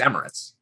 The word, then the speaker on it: Emirates: Emirates? Emiratis? I think it's Emirates.